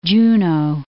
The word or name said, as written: Juneau